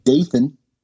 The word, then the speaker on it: Dathan: And number two, find funny shit that we didn't bring up and put it in the comments because I like the name Dathan because you can, it's spelled Nathan, but with a D.